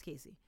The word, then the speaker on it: Kasi: Um, so, Cassie is one of my absolute favorite. I said Cassie. Her name is Casey. Sorry. We're thrown off because it's spelled K A S I and it's pronounced Casey.